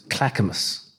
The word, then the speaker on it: Clackamas: I went out to, I'm going to mispronounce it, Clackamas. – You got it.